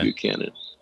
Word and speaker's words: Buchanan: B-U-C-H-A-N-A-N. Lake okay. – Buchanan.